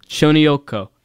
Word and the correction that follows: Tsuchinoko: Oh, no. Tsuchinoko.